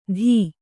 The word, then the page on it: ಧೀ: dhī